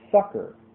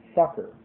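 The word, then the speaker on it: succour: Then a word which very few people pronounce properly, S-U-C-C-O-U-R. S-U-C-C-O-U-R. This word is properly pronounced sucker, sucker,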